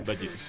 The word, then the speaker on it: Vagsus: A-G-S-U-S. En el SoundCloud la misma, SoundCloud/Vallisus. Tenemos dos temas nomás. Claro, se escribe Vaxus, con G. – Va Jesús. – Bueno, aquí los muchachos de la Real Academia de ellos. – De Vallisus. – Vaxus se pronuncia Vallisus, por si acaso.